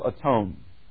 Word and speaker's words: atone: 400 years ago, you didn't say the word one the way we pronounce it. You said the word own. Own. You hear that in atone.